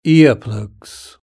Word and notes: ear plugs: British English

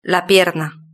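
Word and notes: la pierna: Spanish (Spain)

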